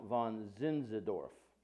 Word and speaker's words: Zinzendorf: Taking the case for the Christians was a Monrovian missionary by the name of Count von Zin, I have a hard time pronouncing his name, but it's Count von Zinzendorf.